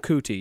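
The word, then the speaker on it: Kuti: F-E-L-A-K-U-T-I. Are we even supposed to say that on the air? – Oh, it's a first name Fella, last name Cootie. Yeah, that guy. Okay. – Fella Cootie.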